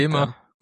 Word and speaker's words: Gemma: Okay, also das Google Gemma sagt über sich selber, dass wie ein hartes G ausgesprochen wird. Das heißt, es ist nicht ganz klar jetzt. So. Gemma sagt Gemma.